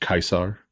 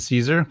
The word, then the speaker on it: Caesar: So are we going to be pedants and say his name should really be pronounced Kaisar? – Kaisar? I don't know. How do you pronounce it, Caesar? Welcome to Laura Spears Podcast. – Hello, fathers. Thank you for taking my call. Yeah, Caesar. I mean, it depends on the language. Yeah, Caesar or Kaisar in German or whatever.